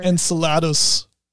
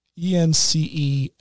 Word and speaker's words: Enceladus: Plumes of water erupt from Europa and in, how do you pronounce that, enchilada? It's Europa and... – You know, I'm not 100% sure. – Enceladus. Enceladus. I'll go with enchilada.